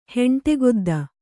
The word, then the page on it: ಹೆಂಟೆಗೊದ್ದ: heṇṭe godda